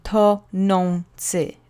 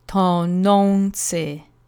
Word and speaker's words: tonący: So on is the sound. To-non-ci. Three syllables. The accent goes on the middle syllable, the penultimate syllable, as usual. And let's say together slowly three times. Tonący.